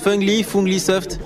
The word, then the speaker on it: Funglisoft: Comment on prononce Funglisoft? – Alors, c'est la Funglisoft. Fun comme fun en anglais, et Glee, soft.